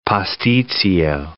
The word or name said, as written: pastitsio